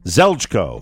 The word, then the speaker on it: Zeljko: It is spelled Z-E-L-J-K-O. Zeljko.